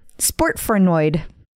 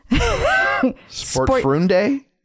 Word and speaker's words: Sportfreunde: Sportfrenoid. – Frenoid? – Sure. – Okay. – Sportfrenoid. – I think you're adding syllables. Sportfrunde? – See?